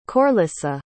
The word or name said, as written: Corlissa